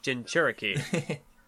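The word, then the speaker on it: Jinchūriki: No, because I think in the anime they just use the word Jinchuriki or No, it's a CH. Like, I always got it confused by pronunciation, but I saw it spelled here, so it's Jinchuriki. – Jinchuriki.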